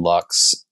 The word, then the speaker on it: Lux: So yeah, my name is Matthew Lux. That's a very American way of pronouncing that last name.